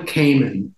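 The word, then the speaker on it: Kamman: Madeline Kamen.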